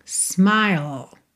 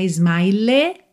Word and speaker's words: smile: Atenção aí à pronúncia dessa palavra, S-M-I-L-E, não é smile, nem smiley, tá? Smiley é o nome daquela carinha amarela, feliz, né? Aquele é o smiley, tá? Smiley face. Mas aí é porque nós temos L-E-Y no final, tá? Aqui é o sorriso, smile, smile.